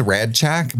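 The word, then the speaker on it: Rasczak: It's kind of a D sound, Radchak. – Radchak. – But it's spelled with like an S-Z-C. – Yeah, it's R-A-S-C-Z-A-K.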